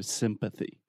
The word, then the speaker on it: sympathy: So sympathy if you don't say the th, this could be confused with symphony, which is an orchestra. So sympathy.